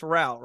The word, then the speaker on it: Rao: Anjali Rao, everybody. Did I say the last name right too? It's Rao, right? – Perfect. Perfect. Yes, it rhymes with wow.